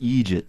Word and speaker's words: eejit: E-E-J-I-T, I think you spell it. Yeah. That would be idiot for our It would, yeah. Except in Ireland, we tend not to bother with the extra syllable if at all possible.